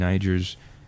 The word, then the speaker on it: Niger: And they are also the criteria for victors in Niger's traditional Garawal festival. – Are you sure that's how you pronounce that country? – Yes.